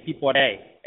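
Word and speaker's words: Piporé: This little accent means, it gives it kind of like a sharp pronunciation. Pipore. A gives it the stress on the E.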